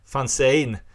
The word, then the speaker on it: fanzine: Detta är ju ett ett fanzin och det... Äh, ursäkta det, det korrekta uttalet är fanzine.